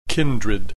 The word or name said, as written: kindred